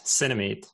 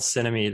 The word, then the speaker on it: Cinemeet: So the app is called Cinemeet. It's spelled like cinema and meeting somebody. So Cinemeet.